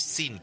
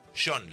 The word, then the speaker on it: Sean: Porque en la final contra Francia, mientras escuchamos Beautiful Boy de Lennon, tema dedicado a John, a su hijo, no es Sean, no es Sin, por favor, es John, la versión escocesa de Juan. Sean, se pronuncia Sean, como Sean Connery.